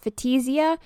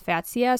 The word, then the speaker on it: Fatsia: I used to pronounce it Fatisia, but I looked it up online and it's Fatsia. So it's literally pronounced just like it's spelled F-A-T-S-I-A.